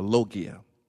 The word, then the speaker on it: logia: and is added to the suffix logia, which means to study, or logia, as you would pronounce it in Greek.